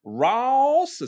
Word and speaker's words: Ross: my mom she has my name as a four syllable ro- ro- word. She goes Ross. It's like R A W S.